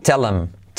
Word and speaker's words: tell them: Tell them, letra clave L. La TH se omite. ¿Y qué queda? Tell them, tell them, tell them.